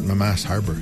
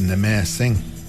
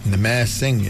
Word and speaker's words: Namassing: It's not Mamass Harbor. He said, it's Namassing. Namassing, it means it's the blessed place.